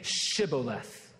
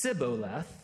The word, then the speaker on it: Shibboleth: And if he replied, "No," they said, "All right, say Shibboleth." And if he said Sibboleth, because he could not pronounce the word correctly, They seized him and killed him at the fords of the Jordan.